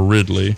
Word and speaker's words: Ridley: Yeah, so like one couple that we've talked about before was Latimer and Ridley. Am I saying that right? – That's right.